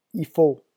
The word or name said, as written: il faut